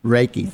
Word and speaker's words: Reiki: Am I pronouncing that right? – Reiki, thank you. See, I knew I screwed it up.